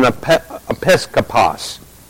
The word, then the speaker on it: episkopos: But that term, bishop, when we take a look at it, is again the example of one who is an episkopos.